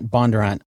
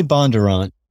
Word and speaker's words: Bondurant: You say Bondurant, but a lot of people in Franklin County say Bondurant. They sort of allied the last a little quicker, Bondurant.